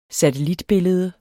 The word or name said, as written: SATELLITBILLEDE